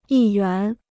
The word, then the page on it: 议员: yì yuán